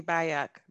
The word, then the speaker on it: Bayoc: Bobby Bayok, B-A-Y-O-C.